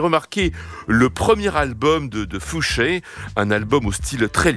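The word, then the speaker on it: Foushée: L'air qu'on vient d'entendre est signé par une chanteuse qui signe tout simplement de son nom de famille, Fouché, qui s'écrit F-O-U-S-H-E-E, avec étrangement l'accent aigu sur le dernier E, celui de la fin, quoi.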